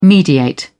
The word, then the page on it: mediate: British English